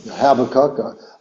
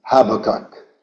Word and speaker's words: Habakkuk: We emphasize the first syllable, the professor liked the second syllable, but the Hebrew is on the third syllable. It's and the first letter of Habakkuk is not just an H, it's a CH, which is a guttural sound, like ha, ha, ha, ha, ha.